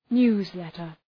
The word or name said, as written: newsletter